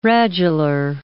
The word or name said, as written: radular